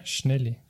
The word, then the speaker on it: Schnelli: seekord oli pargis põhimõtteliselt, Schnell, Schnelli, Schnelli, kuidas hääletatakse mm. Ah. -hmm., Schnelli pargis. – näe, oligi vaja meil seda harjutust. aa, oli vaja, Schnelli, kui see on õige hääldus.